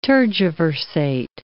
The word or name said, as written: tergiversate